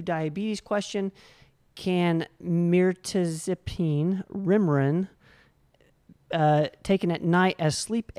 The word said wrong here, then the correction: Remeron: I might have mispronounced half of those words. – Remeron. Yeah, we use a little bit of that.